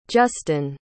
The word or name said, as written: justin